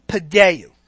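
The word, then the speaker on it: paideu (παιδεύω): And the first word is paideu. P-A-I-D-E-U-O with an accent. And the other word is mastigo. M-A-S-T-I-G-O-O, accent on the O. Paideo comes from the word pious, which is to instruct, discipline, or chastise.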